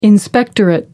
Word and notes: inspectorate: American English

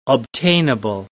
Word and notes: obtainable: American English

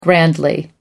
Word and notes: grandly: American English

